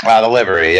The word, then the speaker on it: The Livery: correct me if I'm saying this wrong, Livery? – The Livery, yeah. – The Livery, okay.